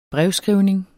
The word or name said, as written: brevskrivning